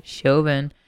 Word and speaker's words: Chauvin: Kind of just want to, like, point out that Derek Chauvin, however the fuck you say his name. – Chauvin was found guilty in the death of George Floyd.